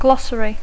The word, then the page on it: glossary: English